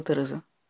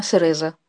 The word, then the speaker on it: Тереза: «А вас как зовут?» – спросила Тереза. «По имени». «Мое имя?» «Кэрол». «Пожалуйста, не называйте меня Кароль». «Пожалуйста, не называйте меня Сереза», – сказала Тереза, произнося английское «т» как «с».